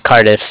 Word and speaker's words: Cardiff: How do you say Cardiff? – Yeah, Cardiff, C-A-R-D-I-F-F, as in Frank.